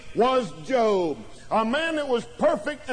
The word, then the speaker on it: Job: That's J-O-B, looks like Job, but it's pronounced Job. J-O-B in your Bible.